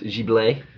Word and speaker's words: Ghibli: But, it's actually pronounced Ghibli.